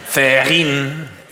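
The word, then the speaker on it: Cehegín: Tiene Cejín. ¿Cómo es? – ¿Cómo lo has dicho? – Cejín. – Cejín. – Cejín, bueno. – Cejín. – Pero hay una H intercalada que seguro que la pronunciáis.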